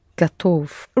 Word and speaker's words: готов: You have two O letters, but it's only the second one that is pronounced as O. Right? Because the first one is pronounced as R, it's not accented. So it's готов. But you know the spelling now, it is готов.